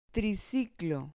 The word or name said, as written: triciclo